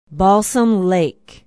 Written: balsam lake

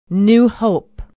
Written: new hope

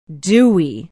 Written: dewey

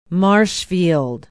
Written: Marshfield

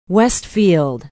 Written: westfield

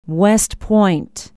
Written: west point

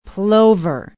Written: Plover

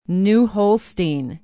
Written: new holstein

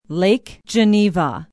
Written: Lake Geneva

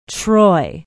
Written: troy

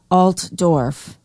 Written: altdorf

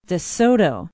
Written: de soto

